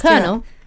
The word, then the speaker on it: colonel: And apparently this, how do you say that? Colonel. – Colonel. – Is that how you spell colonel? – Yeah. – Funny spelling, isn't it?